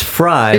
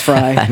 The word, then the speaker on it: Frey: It's Fry. – It's but, Fry. Uh, um, it's spelled it's Frey. Pronounced Frey. – Um, Glenn, not pronounced. It's spelled. – Frey. It's spelled Frey. Pronounced Fry.